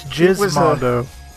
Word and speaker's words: Gizmondo: I just use Gizmondo as a sex move, but... – No, that's Gizmondo. – Oh, I've been mispronouncing it this whole time. – It's a soft G, John.